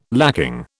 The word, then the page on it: lacking: British English